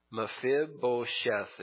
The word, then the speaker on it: Mephibosheth: Now, I said it twice. I shouldn't have done that. Mephibosheth is how you say it.